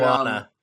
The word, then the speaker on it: Moana: And this is also the same guy who did the soundtrack and music for Mona. I think that's how you pronounce it. Yes, because that's one of my favorite. – Moana. – Moana. I keep forgetting how that's pronounced because the spelling and the pronunciation are so different.